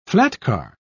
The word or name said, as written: flatcar